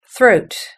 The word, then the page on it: throat: British English